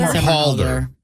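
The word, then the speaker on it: Somerhalder: How do you say his name? Is it Summerhalder? – Ian Summerhalder. – Halder.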